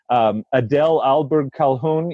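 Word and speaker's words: Adele Ahlberg Calhoun: I like the words are coming to me of Adele Alberg Calhoun. You introduced me to her writings. Is that, am I saying her name right? – I believe so, yes.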